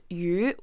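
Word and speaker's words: yu (鱼): So the word for fish in Chinese is pronounced yu, which is a homophone for a different character that is also pronounced yu and means surplus or abundance.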